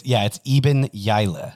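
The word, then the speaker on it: Iben Hjejle: Her last name is spelled H-J-E-J-E or something, but it's Eben Yaila.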